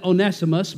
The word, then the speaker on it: Onesimus: Now, Brother Mike, I pronounce it Onesimus,